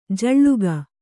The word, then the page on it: ಝುಳುಕು: Kannada